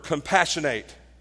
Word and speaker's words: compassionate: We say he's a compassionate person. But there's another word, a derivative that is spelled the same as compassionate, but it's pronounced differently, and it's a verb, compassionate.